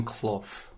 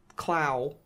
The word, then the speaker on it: Clough: By the way, it's pronounced Brian Clough. – Clough. – Yeah. – Clow.